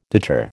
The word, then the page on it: deter: American English